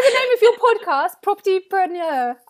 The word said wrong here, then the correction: Propertypreneur: I'm terrible. – Propertypreneur.